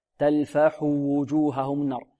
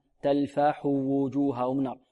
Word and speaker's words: تلفح: تلفح وجوههم النار، تلفح وجوههم، لا ينطقها الطالب بخطف الضمة هنا، يقول تلفح وجوههم، تلفح وجوههم، أو ربما يسكن الحاء، تلفح وجوههم، لا، لابد من إتيان بالضمة كاملة، تلفح وجوههم النار، تلفح وجوههم النار.